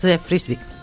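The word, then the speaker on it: Frisbey: Astrid Berges Frisbee. – Vale, frisbee es de lo de toda la vida. De frisbee de tirar el frisbee.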